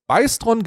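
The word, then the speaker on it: Bystron: Der wird Büstron ausgesprochen, der heißt Beistron genau wie der andere Bernd heißt.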